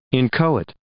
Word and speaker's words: inchoate: So inchoate is the word that's spelled I-N-C-H-O-A-T-E. – Yes. – Cool. – Inchoate. – Oh, and we keep saying, or I keep saying inchoate, but the little pronouncer here on Merriam-Webster anyway says inchoate.